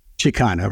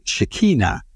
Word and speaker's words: Chicana: So the first time I said it right, but the second time I mispronounced it totally. So what I said instead of Chicana, I don't know if you heard it, but I said Shekinah.